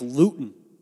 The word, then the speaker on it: Luton: It's like Mike Luton, rhymes with gluten, is going to be the starting quarterback for the Jaguars.